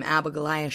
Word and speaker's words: Abigoliah: And it's I'm Abigolia Shaman, like what Michael Jackson used to say, Shaman.